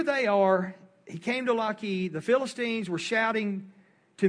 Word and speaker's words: Lachish: And so it says, when he came to Lachish, which, and this is, this Lachish is how it's pronounced, is not far from Zorah.